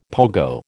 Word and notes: pogo: American English

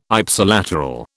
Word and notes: Ipsilateral: British English

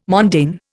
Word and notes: mondaine: Indian

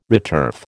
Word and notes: returf: American English

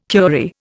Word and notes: curie: Indian

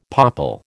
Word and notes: popple: American English

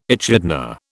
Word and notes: echidna: British English

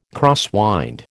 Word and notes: crosswind: American English

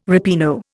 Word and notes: ripieno: Indian

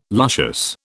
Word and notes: luscious: British English

